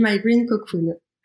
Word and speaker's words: My Green Cocoon: La première, on prononce comment Green cocoon, cocon C'est c'est à l'anglaise, donc on dit my green cocoon.